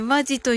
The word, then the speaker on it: 本気: まあ、あの、ひろしさんのひろしさんのあの、本気と書いてね、マジと読むところの、あの、レターいただレター、あの、可能であればいただいてよろしいでしょうか、今。